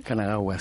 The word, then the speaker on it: Kanagawa: Una gran ola de cara Kanagawa, si se pronuncia así. – Kanagawa, sí.